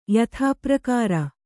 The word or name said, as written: ಯಥಾಪ್ರಕಾರ